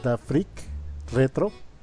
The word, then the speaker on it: Da Freak Retro: Su canal de YouTube, que se llama Da Freak Retro, así como lo pronuncié, Da Freak, Da Freak Retro.